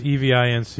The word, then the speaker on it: Evince: But, you know, I prefer Events, but that's just me. – Yeah. – I will look at it. Is it spelled like it sounds? Evince? – Yes, Evince. And if you're running Debian, it should be a simple app getaway.